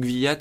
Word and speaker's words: Kvyat: Vous écoutez bien Analyse F1 et nous sommes de retour pour parler du pilote russe, donc Daniil Kvyat ou Kvyat, mais je crois que ça se prononce Kvyat. Hein. En tout cas, en 2014, on y prononçait Kvyat. Après, en 2015, certains commentateurs prononcent Kvyat. Je vais dire Kvyat, voilà, tout simplement.